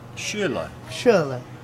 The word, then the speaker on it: Schürrle: Back to Chelsea, they signed Van Ginkel, who played for the under 21s Netherlands, and Schürrle. Is that how you pronounce it? You Schürrle.